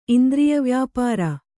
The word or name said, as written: ಇಂದ್ರಿಯವ್ಯಾಪಾರ